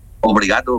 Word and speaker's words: obrigado: ¿Cómo, obligado, sí, ¿cómo se dice? Obligado es gracia, ¿no? – Obrigado, gracias.